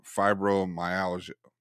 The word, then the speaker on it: fibromyalgia: It's every time I try to say it, I butcher the name, fibromyalgia.